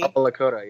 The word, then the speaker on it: Oglala Lakota: You've mentioned that you are Chicano as well as Oglala Lakota, if I'm pronouncing that correctly. – Oglala Lakota, yeah.